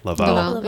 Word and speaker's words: Laval: Am I pronouncing that right? Laval. Yeah. Laval location, which he started in.